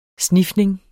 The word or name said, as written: snifning